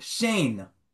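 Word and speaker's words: shein (שן): Singular tooth is called shein.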